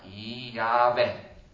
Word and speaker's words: Yahweh: Da dove le peschi le vocali per far diventare quella scritta Yahweh? Cioè la A, prima vocale, e la E, ultima vocale. Allora, Yahweh.